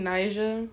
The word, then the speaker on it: Naija: You know who Queen Najee is? – Queen Naija. – Is that how you say her name? – Yeah. That's how you say that girl name.